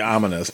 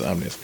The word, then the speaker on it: Omnius: I always say it like ominous, but it's not ominous. Omnius.